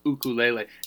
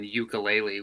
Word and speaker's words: ukulele: And so it turns out that there's only one vowel sound for each vowel in Hawaiian. So there's no long and short vowels. So it ends up being ukulele is the U's and E's are pronounced that way. So it's ukulele as opposed to the common ukulele, which has kind of become the more common way to say it.